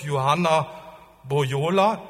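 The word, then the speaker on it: Juhana Pohjola: ich hoffe, ich spreche seinen Namen richtig aus, Bischof Johanna Bojola, ist das so richtig ausgesprochen? Gut.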